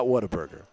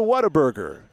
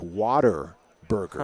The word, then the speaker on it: Whataburger: And by the way, it's not Whataburger. I said Whataburger as the Tech announcer when I would read the game reads. Go to Whataburger, because that's how it's spelled. In West Texas, they pronounce it almost like water burger. Huh. Like you're drinking a burger doused in H2O, Whataburger. So if you want to say it like a true Texan, it's Whataburger.